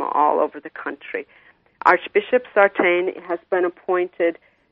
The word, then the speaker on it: Sartain: Sartain, yes.